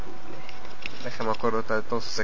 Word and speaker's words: Bublé: Algunos dicen que Bublé porque tiene acento en la E, pero bueno, como lo entiendan, es el mismo güey. – Ah, pues el músico se dice Bublé.